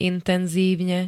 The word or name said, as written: intenzívne